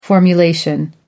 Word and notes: formulation: British English

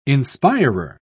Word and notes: inspirer: British English

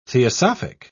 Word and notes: theosophic: British English

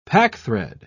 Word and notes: packthread: British English

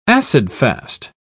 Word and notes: acid-fast: British English

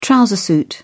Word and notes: trouser suit: British English

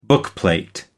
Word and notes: bookplate: American English

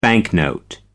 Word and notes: banknote: American English

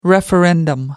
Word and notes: referendum: American English

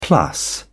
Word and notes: plus: British English

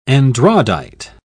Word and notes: andradite: British English

